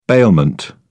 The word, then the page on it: bailment: British English